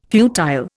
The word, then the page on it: futile: Indian